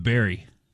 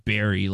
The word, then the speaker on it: Barry: And this guy came in with Barry. – It rhymes. – Actually, there is a Barry name, but the way you said it was like berry.